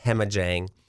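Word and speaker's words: Jhemajang: So it's J-H-E-M-A-J-A-N-G, Hemajang.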